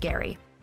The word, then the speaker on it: Gerry: Yes, it's pronounced Gerry.